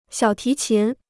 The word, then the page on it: 小提琴: xiǎo tí qín